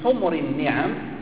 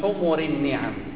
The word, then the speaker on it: حمر النعم: قول النبي صلى الله عليه وسلم: لئن يهدي الله بك رجلا واحدا خير لك من حمر النعم، لا يقال من حمر النعم كما ينطقها بعض من يقرأ هذا الحديث، من حمر النعم يعني لا يستقيم، يعني يصبح المعنى جمع حمار.